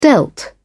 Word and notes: dealt: British English

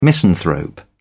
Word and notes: misanthrope: British English

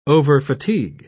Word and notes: overfatigue: British English

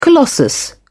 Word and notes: colossus: British English

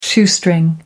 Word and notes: shoestring: American English

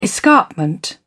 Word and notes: escarpment: British English

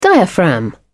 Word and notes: diaphragm: British English

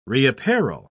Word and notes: reapparel: British English

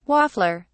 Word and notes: waffler: British English

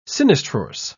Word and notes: sinistrorse: British English

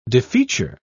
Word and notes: defeature: British English

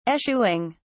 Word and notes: eschewing: British English

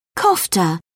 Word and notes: kofta: British English

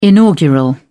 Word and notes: inaugural: British English

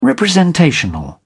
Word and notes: representational: British English

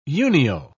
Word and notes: unio: British English

